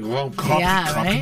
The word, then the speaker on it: Grand Prix: Grand Prix Montreal. Right? That's how you say it.